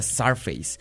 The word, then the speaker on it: Zzarface: Que yo antes le decía C-Sarface porque se escribe Z-Z-A-R-F-A-C-E. Lo dije todo mal, pero bueno. Eh, se pronuncia Sarface.